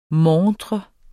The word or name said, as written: MONTREN